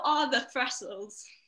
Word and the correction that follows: thestrals: It's what are thestrals? – Oh, basically said the same thing. I just pronounced it wrong.